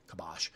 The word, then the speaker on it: kibosh: The kibosh, kibosh, I always say it wrong. Kibosh. So, anyway, I think that's something we need to take away from this.